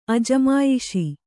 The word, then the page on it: ಅಜಮಾಯಿಶಿ: ajamāyiśi